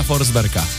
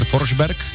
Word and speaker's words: Forsberg: Jak byste vyslovili jméno legendárního Petra Forsberga? Tak možná vás napadá třeba Peter Forsberg? No vlastně svým způsobem ano, ale pozor, správně by to údajně mělo v té švédštině znít Peter Forsberg. Ano, skutečně. Peter Forsberg je Peter Forsberg, protože Švédové změkčují i jména končící na G.